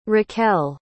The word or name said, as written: raquel